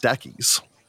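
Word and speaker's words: Stachys: Had stated that Statues is actually pronounced Stackies and is named after a plant genus.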